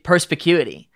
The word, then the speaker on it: perspicuity: He's going to say perspicuity as many times as he can in this interview, just to like prove that he can say it correctly. – Yeah, yeah. and and I think thus far You've I haven't it. done it yet. Every time. – No, you've nailed it.